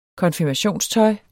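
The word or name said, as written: konfirmationstøj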